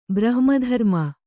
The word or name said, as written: ಬ್ರಹ್ಮಧರ್ಮ